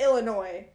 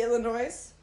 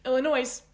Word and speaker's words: Illinois: do you not pronounce the S in Illinois? Like, it's Illinois. But like, it should be Illinois. – Illinois.